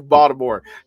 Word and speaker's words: Baltimore: When you from Baltimore, you Baltimore. The T is silent.